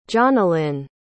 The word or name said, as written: jonalynn